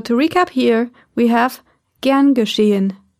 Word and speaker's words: gern geschehen: Another way of responding to a phrase of gratitude is gern geschehen, which means with pleasure. Gern geschehen.